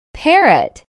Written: parrot

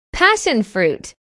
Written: passion fruit

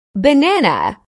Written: banana

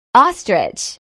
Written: ostrich

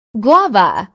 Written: guava

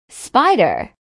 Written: spider

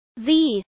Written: z

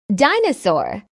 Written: dinosaur

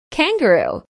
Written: kangaroo

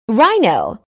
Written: rhino

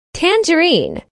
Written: tangerine

